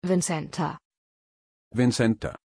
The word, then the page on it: vincenta: German